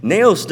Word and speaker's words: naos: But again, we are the temple of God, and the Greek word used here for temple is naos. It's spelled N-A-O-S, naos.